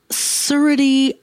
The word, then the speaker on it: surety: It is surety bond, or some people pronounce it surety bond, mm. but there is no H in it.